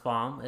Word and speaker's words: Pham: So my last name is Pham, but it's pronounced Pham. It's a Vietnamese last name.